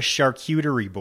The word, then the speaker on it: charcuterie: How do you say it? I ask you all the time. I say it wrong. What is it called? – It's called a charcuterie board.